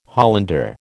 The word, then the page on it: Hollander: American English